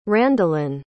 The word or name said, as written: Randilyn